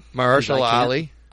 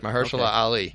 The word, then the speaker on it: Mahershala Ali: I'm going to let you say his name. – Mahershala Ali. – Mahershala? Yeah. – Yes. – Is that right? – Mahershala Ali. – Mahershala. – Yeah, Mahershala Ali.